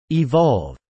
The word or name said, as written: evolve